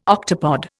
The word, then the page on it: octopod: Indian